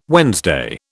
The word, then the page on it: Wednesday: British English